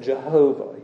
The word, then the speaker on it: Jehovah: Some pronounce it Yahweh, Yahweh. Uh, others have put uh, vowels in there from Adonai, and they pronounce it Jehovah.